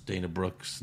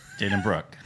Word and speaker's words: Brooke: By the way, we've been doing this podcast for almost 5 years, and you still pronounce Dana Brooks' name wrong. – Yeah. – Dana Brooke. – Yeah, hey.